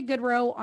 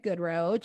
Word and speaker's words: Goodroe: Well, I'm at Rita Goodrow on like every social media platform, Instagram, Facebook, and of course, Clubhouse. – Okay. And spell your last name because people spell it wrong. – It's G-O-O-D-R-O-E, Rita Goodrow, G-O-O-D-R-O-E.